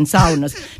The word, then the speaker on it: sauna: Is that pronounced correctly? – Yes, I think we have like 5 million people in Finland. We have 2 million saunas, because you have one sauna in your house, in your city house, and then another one in your cottage.